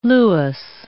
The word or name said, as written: Lewis